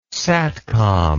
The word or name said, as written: satcom